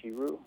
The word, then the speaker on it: Shirow: Part of that is because the author, Masamune Shiru, I might be butchering his name, but I think that's how it's pronounced, has been very open with the idea of, in the book, I just kind of wanted to draw something expressive.